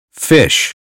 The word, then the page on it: fish: American English